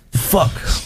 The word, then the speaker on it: Phuk: I want to start a band called Fuck, but it's spelled P-H-U-K. – Took a minute for that to P-H-U-K. – Fuck.